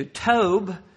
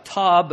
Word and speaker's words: Tob: He fled to Tob, T-O-B, sort of sounds like Tob. I understand it's pronounced Tob.